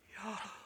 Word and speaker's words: Yahweh: The name Yahweh, it's breath. Yahweh. Yahweh. It's a inhale and an exhale. Yahweh. That's how you say it in Hebrew.